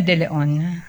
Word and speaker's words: De Leon: Sonia De Leon. – De Leon. – Yes, sir. Yes, Your Honor. – D-E-L-E-O-N.